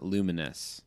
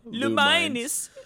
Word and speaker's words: Lumines: And also, Limited Run announced, like, I think today even, I've always pronounced it Luminous, but I don't think that's what it is pronounced. I don't know. – We can roll with correct? – That. – Lumines, maybe? Lumines? Lumines? – Lumines. Lumines. – Lumines. – Lumines. – I think it's Lumines or Lumines, whatever.